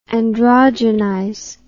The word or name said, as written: androgenize